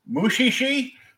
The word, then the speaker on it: Mushishi: Mushishi? – Actually, you pronounced that right.